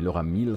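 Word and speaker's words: Miele: Euh, c'est l'arrivée donc de Laura Mill, M-I-E-L-E, e, donc Miel, mais Laura Mill, ça se prononce comme ça, au rang de directrice des opérations, donc au rang de numéro 2 de l'édifice, de l'immense édifice Electronic Arts.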